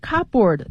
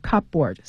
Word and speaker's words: cupboard: 今天我们要听到的这个对话之中呢，有一个客户他正在报告一个需要维修的东西，是一个住的地方需要维修。好，那我们开始之前呢，先来预习一下生词。第一个是 cupboard， cupboard 其实就是壁橱，它是 cup C-U-P 然后 B-O-A-R-D，但是 cupboard 这个 P 是不发音的，因为它连读了，所以呢它就不出声音了。 cupboard C-U-P-B-O-A-R-D。第二个单词是 the main control switch，